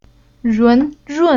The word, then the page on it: run: Chinese